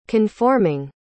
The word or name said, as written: conforming